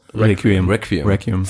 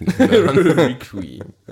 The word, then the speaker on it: Requiem: Spricht man das so aus? – Requiem. – Fuck, warum bin ich so schlecht? – Requiem. – Ja.